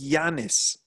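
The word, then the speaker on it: Ianis: I think it's Yanis, isn't it? – Is that how it's pronounced? – Because I think in Romanian, an I has a U sound. So I think it's Yanis Hadji.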